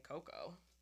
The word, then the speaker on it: cacao: I think it's spelled, cow-cow. It's spelt like cow-cow, no? But I would say cocoa.